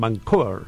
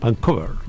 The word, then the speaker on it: Vancouver: Habré pronunciado bien Vancouver. – ¿Cómo? – Vancouver. – Vancouver.